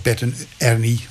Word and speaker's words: Bert en Ernie: En dat wel als Bert en Ernie, want zo moet ik het uitspreken, mochten uitbrengen.